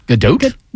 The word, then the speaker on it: Gadot: It's like boat or doat. – You know, I've heard her say it three different ways. – Gadot?